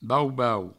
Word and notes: bau-bau: Provençal

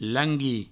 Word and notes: languir: langui; Provençal

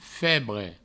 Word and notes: febre: Provençal

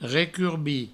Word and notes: recurbir: recurbi

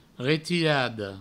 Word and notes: retirada: retirado; Provençal

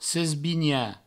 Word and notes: sesbinhar: sesbigna

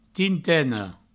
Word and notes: tinteina: tinteino

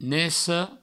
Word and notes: neca: neco

